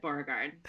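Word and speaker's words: Beauregarde: Definitely Violet Beauregard. Did I say that right? – Yeah. Yeah, you did.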